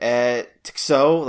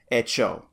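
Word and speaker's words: Eh Txo: Because it's spelled E-H space T-X-O. And I was like, Tixo? Like, what the hell? But in the song, I think it's Echo.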